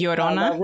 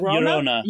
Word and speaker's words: Llorona: But Allah, and I'm going to mispronounce it. – Llorona. – Llorona. – Yes. Is the weeping woman.